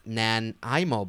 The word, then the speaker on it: Nanaimo: And then Nanaimo bars, pronounced Nanaimo bars.